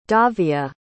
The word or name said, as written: davia